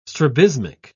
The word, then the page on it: strabismic: British English